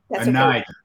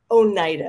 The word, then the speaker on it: Oneida: Oneida. Oneida, and it's O-N-E-I-D-A, so it's quite easy to mispronounce it.